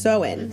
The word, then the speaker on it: Samhain: And she opened the book to Samhain. Okay, I apologize now. – No, it says pronounced Soin. – Soin, just kidding.